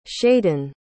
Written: shayden

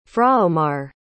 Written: fraomar

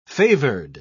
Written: favored

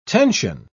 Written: tension

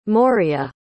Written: moria

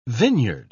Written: vineyard

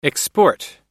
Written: export

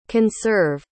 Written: conserve

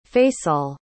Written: faysal

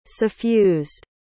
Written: suffused

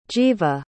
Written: jiva

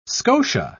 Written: scotia

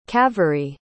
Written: kaveri

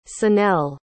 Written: sonel

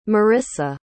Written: Marisa

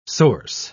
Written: source

